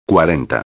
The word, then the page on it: 40: espanhol